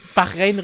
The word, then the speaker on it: Bahrain: Bachrhein war auch nicht das beliebteste Rennen, das es zum ersten Mal im Kalender gestanden hat. – Bis es dann Christian, richtig ausgesprochen wurde bis es Bahrain richtig ausgesprochen wurde.